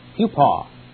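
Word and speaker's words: hupo (ὑπό): Well, the word under there is the Greek word hupo, not hupo, hupo. It's an omicron, kind of like coffee. It's pronounced kind of like the O in coffee.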